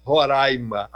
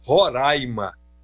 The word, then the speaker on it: Roraima: Venha conhecer, você não vai se arrepender de conhecer Roraima. Aliás, a gente aqui é bairrista ao pedir que as pessoas pronunciem Roraima e não Roraima. – Tá bom? Roraima, falei correto agora.